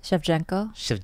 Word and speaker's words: Shevchenko: Shevchenko.